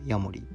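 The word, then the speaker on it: 家守: 漢字で書くと「家を守る」って書いてヤモリって読むんですよね。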